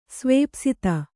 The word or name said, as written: ಸ್ವೇಪ್ಸಿತ